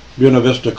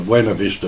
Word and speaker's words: Buena Vista: that is the one that's published in Buena Vista, Colorado. I'm always tempted to call it Buena Vista, but there they pronounce it Buena Vista, Colorado, by the Women's Monastery, or Bishop Gregory's Men's Monastery.